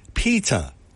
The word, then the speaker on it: Petter: His name is pronounced Pita. Remember that Betty Davis movie where she said Pita, Pita, Pita? Well, that's it, Pita.